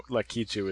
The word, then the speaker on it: Lakitu: You'd pronounce the K-I as Ki if it was Japanese, so I'm presuming you borrow it from that, but my American tongue wants to say Lakitu.